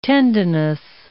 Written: tendinous

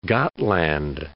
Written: Gotland

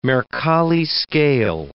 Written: Mercalli scale

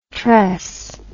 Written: tres

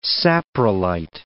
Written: saprolite

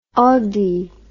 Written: on dit

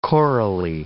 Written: chorally

